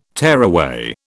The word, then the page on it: tearaway: British English